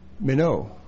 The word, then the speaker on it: meno: It's spelled M-E-N, long O, meno.